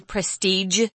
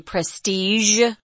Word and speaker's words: prestige: I wanted to mention prestige is sometimes pronounced that way, prestige, with a J sound at the end. But most people I know say prestige.